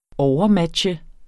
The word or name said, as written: overmatche